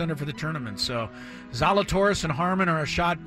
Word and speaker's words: Zalatoris: We don't even know that his name is pronounced Zalatoris.